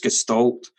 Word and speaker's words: gestalt: It stimulates a thing called gestalt, which is G-E-S-T-A-L-T. People pronounce it differently. Being Scottish, it's gestalt. There's gestalt. There's a whole load of other pronunciations, which is why I spell it out.